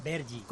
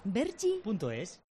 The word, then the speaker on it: Vergy: Vergi. – ¿Cómo se escribe? – V-E-R-G-Y. – Vergi.es.